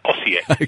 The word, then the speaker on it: Osijek: Houghton and Osijek.